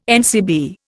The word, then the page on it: NCB: Indian